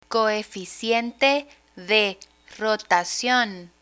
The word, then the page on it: coeficiente de rotacion: spanish